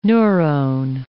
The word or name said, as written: neurone